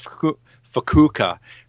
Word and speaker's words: Fukuoka: They're from, I think it's pronounced Fukuoka, Japan? Fukuoka? – I think it's They've, Fukushima, but go on. No, it's not. It's not. I'm pretty sure it's Fukuoka.